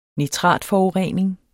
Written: nitratforurening

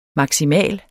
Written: maksimal

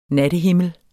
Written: NATTEHIMMEL

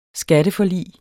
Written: skatteforlig

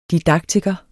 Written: didaktiker

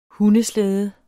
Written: hundeslæde

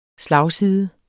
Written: slagside

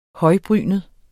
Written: højbrynet

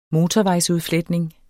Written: motorvejsudfletning

